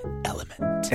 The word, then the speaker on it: LMNT: Element. Is that right? – No. It's the letters L-M-N-T, and it's pronounced Element.